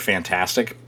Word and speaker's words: FNTASTIC: Uh, Kotaku is reporting that I'm going to just pronounce it uh, as the word fantastic, but it's spelled F N-tastic, uh, which are the developers behind uh, The Day Before, which is the most wishlisted game on Steam.